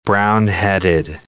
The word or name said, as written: brown-headed cowbird